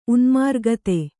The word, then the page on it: ಉನ್ಮಾರ್ಗತೆ: unmārgate